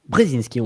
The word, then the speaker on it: Brzezinski: ça se prononce Brzezinski. Comment Brzezinski, on va dire. – On, va rester en francisé.